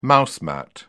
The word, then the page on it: mouse mat: British English